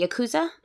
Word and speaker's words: Yakuza: So that North Korean soldier that he supposedly murdered had links to the Yakuza. – Mm-hmm. – Pronounce that right? – Yeah.